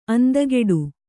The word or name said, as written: ಅಂದಗೆಡು